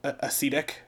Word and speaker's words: ascetic: He's not a ascetic. What is that, how you pronounce it? A-S-C-E-I? – Sure. Like the acid.